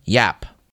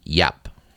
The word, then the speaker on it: Giap: Because the general's last name is spelled G-I-A-P, but it is apparently pronounced Yap.